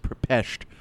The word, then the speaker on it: Budapest: No, it's actually pronounced Pepesht.